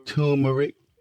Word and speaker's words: turmeric: How you say it again? I'm bad with words. – Turmeric. – Not trauma, though.